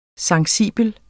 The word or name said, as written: sensibel